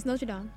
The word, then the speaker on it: Notre Dame: It's Notre Dame.